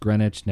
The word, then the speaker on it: Greenwich: I believe it's pronounced Greenwich.